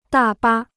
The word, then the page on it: 大巴: dà bā